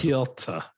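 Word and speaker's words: Petr: I knew you were about to correct me because I messed up his name like several times. – Piotr. Gotta say it right, bro.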